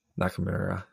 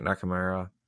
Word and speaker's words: Nakamura: How do you say I say Nakamura. – Nakamura. I like Nakamura.